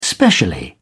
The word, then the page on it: specially: British English